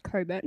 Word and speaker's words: Cockburn: It's actually pronounced Coburn. No one cares. – No one cares. – Cockburn is funnier.